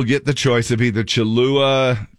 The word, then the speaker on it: Cholula: Cholula.